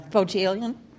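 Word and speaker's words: Bohjalian: Am I pronouncing it correctly? – Bojalian.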